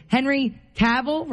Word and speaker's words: Henry Cavill: How do you say this name? Henry Cavill, right? That's how we say it. I don't know why I always think it's going to be like Cavill or something.